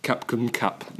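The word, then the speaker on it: Cap cum cap (ขอบคุณครับ): Cap cum cap. Which is how you say thank you in Thai.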